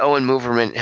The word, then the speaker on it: Oren Moverman: But one of the things that Owen Moverman – am I saying his name right? – Yeah.